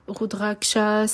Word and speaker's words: Rudraksha: Rudraksha, c'est R-U-D-R-A-K-S-H-A. Mala, M-A-L-A.